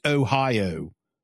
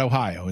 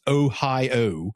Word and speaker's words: Ohio: He'll stress his O's. O's are long O's up there. They're O's. It's Ohio, not Ohio. It's Ohio. And then you'll also hear when he says O-W-N, like down or town, it's downtown.